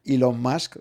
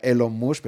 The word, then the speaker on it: Elon Musk: Eh, vamos a llamar a, Elon en su, en su pronunciación inglesa. ¿eh? Elon Musk, eh, en español sería Elon Musk, pero bueno, nos ha parecido que sería lo más, lo más correcto.